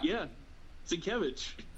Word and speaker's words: Sienkiewicz: How'd you pronounce Bill Sienkiewicz's last name? – Sienkiewicz? Is that how? – Yeah, Sienkiewicz.